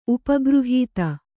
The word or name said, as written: ಉಪಗೃಹೀತ